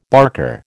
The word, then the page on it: barker: American English